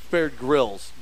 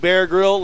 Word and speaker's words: Grylls: No, it's Bear Grylls. G-R-Y-L-L-S. – It's spelled Grylls. – I think it's Grylls. – Dude, are you kidding me, man? You don't know how to, like, Bear Grill? Like a Bear Grill?